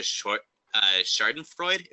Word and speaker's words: Schadenfreude: and it just relishes in the concept of Schadenfreude. Is that how it's pronounced? – Sure.